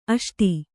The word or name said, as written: ಅಷ್ಟು